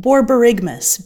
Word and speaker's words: borborygmus: Borborygus? – Borborygmus. B O R. – Miss. Okay. – B O R Y G M U S.